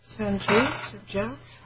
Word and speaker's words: Sanjeev: Okay, but I don't know how you pronounce that name. S-A-N-J-E-E-V. – Sanjeev. – Sanjeev?